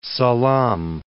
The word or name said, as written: Salam